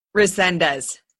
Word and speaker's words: Resendez: Resendez.